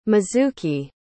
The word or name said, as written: mizuki